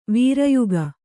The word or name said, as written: ವೀರಯುಗ